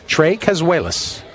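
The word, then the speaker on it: Tres Cazuelas: Trey spelled with an S, not like you. – I figured. – Trey as in three. Three Cazuelas. Trey Cazuelas.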